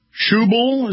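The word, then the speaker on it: Schäuble: Schubel is how you pronounce it.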